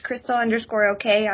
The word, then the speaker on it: Chrystal: My name is spelled kind of funny, but it's Crystal_OK on Twitter.